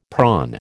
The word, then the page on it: prawn: American English